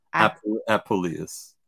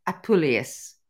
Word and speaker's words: Apuleius: So book number 9, you're going to have to help me out here because as a Brit, you know, we tend to pronounce words somewhat differently than Americans do. – That's so, okay. We'll forgive you for that. – The Golden Ass of Apuleius. – Apuleius. – Yes, yes, yeah.